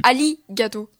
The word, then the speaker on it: Arigato: Alors, ça s'écrit Arigato, mais ça se dit Ali-gato.